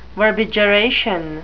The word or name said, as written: verbigeration